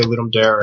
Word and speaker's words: Ludum Dare: I think majority people say Ludum Dare, and so that is the right pronunciation, because majority, mm-hmm.